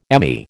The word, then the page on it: Emmy: American English